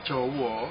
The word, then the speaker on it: Jawa: jadi nah yang pertama adalah tulisan Jowo mm -hmm.